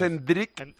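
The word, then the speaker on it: Endrick: Pero yo he hablado con Fernando Calas, que es un compañero brasileño que vive en Marítimo, y me ha dicho que no es Enrique. Es Enrique, pero como alargándola, pero que no es...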